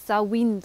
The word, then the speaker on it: Samhain: Sawin wird das Ganze ausgesprochen, Samhain wird es geschrieben.